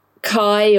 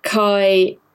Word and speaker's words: Kaj: It doesn't really explain why Kai or Kaj, I think they're pronouncing it Kai in the Danish, but who knows how it's pronounced. It's certainly written Kaj.